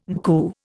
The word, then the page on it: NCU: Indian